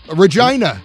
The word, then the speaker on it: Regina: Regina. Rhymes with something else.